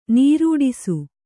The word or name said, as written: ನೀರೂಡಿಸು